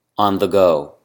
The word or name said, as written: on the go